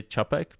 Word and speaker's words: Čapek: We actually say Čapek, but you did pretty good job.